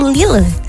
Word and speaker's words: Lille: Lille atau Lille? Sih bacanya Lille ya.